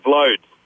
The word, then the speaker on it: Vloed: They couldn't pronounce my surname, so they just pronounced the first five letters. – what's that? Vlod? – V-L-O-E-D, Vlod.